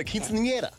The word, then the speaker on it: quinceañera: You throw it a quinceañera. That's how you say it.